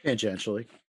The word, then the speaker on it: tangentially: So I want to talk very briefly, and this is tangentially related. I think that's the right way to say that word. – Tangentially. – Tangentially. Thank you. I'm not good. – It's like tangerine.